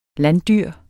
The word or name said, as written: landdyr